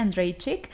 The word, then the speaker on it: Andrejczyk: Ella se llama María Andrejczyk, que, que, lo googleé, mm. así se pronuncia, Andrejczyk.